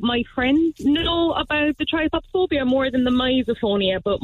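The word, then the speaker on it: trypophobia: So I call it tripopophobia, but I've heard it pronounced other ways, but I think it is that way.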